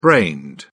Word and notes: brained: British English

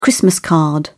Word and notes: christmas card: British English

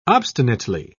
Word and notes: obstinately: British English

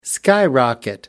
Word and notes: skyrocket: American English

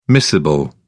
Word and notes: miscible: British English

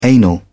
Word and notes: anal: British English

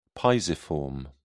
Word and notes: pisiform: British English

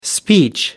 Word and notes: speech: American English

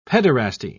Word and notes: pederasty: British English

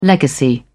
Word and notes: legacy: British English

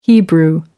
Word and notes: hebrew: American English

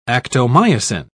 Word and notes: actomyosin: British English

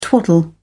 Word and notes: twaddle: British English

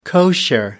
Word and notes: kosher: American English

